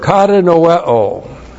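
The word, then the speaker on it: Kata noeo (κατανοέω): The first verb, kata noeo. K-A-T-A-N-O-E long O.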